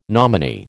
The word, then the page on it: nominee: American English